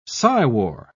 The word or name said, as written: psywar